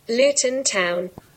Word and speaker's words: Luton: And how do I say it? Pronounce. Here we go. Say that again. – Luton Town. – And let's say that slowly. – Luton Town. – Luton Town. I love it. Hey, you can also get the American pronunciation.